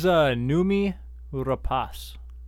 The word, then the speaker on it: Noomi Rapace: Really quickly, I looked up the phonetic spelling, or the phonetic, like, how to say it. It's in Swedish. – Yeah. – So we will... – No, that says, Noomi Rapace.